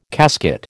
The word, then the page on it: casket: American English